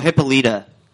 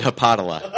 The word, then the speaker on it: Hippolyta: Oh, Hippolyta. That's the name of Wonder Woman's mom. – There you go. – You mispronounced it there. – I've always said Hippodala.